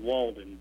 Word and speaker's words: Walden: No, actually, my last name is Walden, D-E-N.